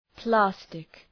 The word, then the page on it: plastic: ‘plæstık